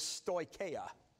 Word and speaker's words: stoicheia: Now, in Greek, the word for these elementary principles is stoicheia. Stoicheia. In English, you'd spell it S-T-O-I-C-H-E-I-A.